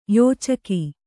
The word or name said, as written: ಯೋಚಕಿ